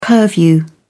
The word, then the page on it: purview: British English